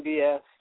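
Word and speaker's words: Deslondes: We walked through the backstory of Charles Dillon, and I butchered his name last week because I don't speak French, so it's Deslonds, and so, but that last name is French, and so I guess they don't pronounce the S's,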